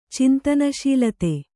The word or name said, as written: ಚಿಂತನಶೀಲತೆ